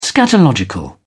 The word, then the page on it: scatological: British English